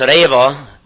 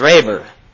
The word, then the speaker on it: Reba: But Amos Reba is a minister in our church. He was never Amos Reber, okay? They were the Reba. It's pronounced R-A-Y-V-A.